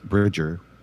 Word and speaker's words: Bridger: So you learn a little bit about that, like that this main character guy that you play, Private Brigger or whatever, he is Bridger. Sorry, I pronounced it wrong.